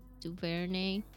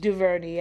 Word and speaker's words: DuVernay: And Ava, how do you pronounce her last name? DuVernay. Should direct it. – DuVernay, yeah.